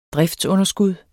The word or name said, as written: driftsunderskud